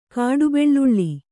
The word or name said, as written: ಕಾಡುಬೆಳ್ಳುಳ್ಳಿ